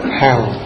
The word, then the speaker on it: HAM: Rồi trên ngực đó là YAM, Y-A-M. Rồi trên cổ đó là HAM, giống HAM ANH đó. HAM. Nhiều người thấy viết H-A-M đọc là HAM. HAM. Mấy cái này nhớ đừng có đọc theo kiểu Mỹ nha, đọc theo kiểu Việt Nam hay là Pháp.